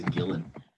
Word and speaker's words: Gillan: Because it's spelled G-I-L-L-A-N. So is it Gillian? – It is Gillian.